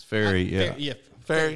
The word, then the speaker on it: Ferrie: Farrell? – It's Ferry, Ferry. How you say it? – F-E-R-R-I-E, is that right?